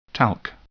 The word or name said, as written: talc